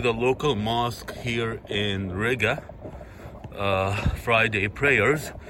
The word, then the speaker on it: Riga: So I'm out here, guys, in Riga, which rhymes with nigga.